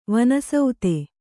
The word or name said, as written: ವನಸ್ಥ